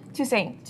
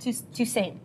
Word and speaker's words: Toussaint: Toussaint. Toussaint.